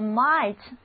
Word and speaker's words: might: 和 may 的意思很相近，还有一个情态动词叫做 might, M-I-G-H-T。字母 I 的读音是 I, 而 G-H 这个字母组合不发音。